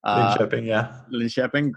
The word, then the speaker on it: Linköping: William Wojcicki, he's playing for, I'm going to say it terribly, to me it says Linköping. – Linköping, great.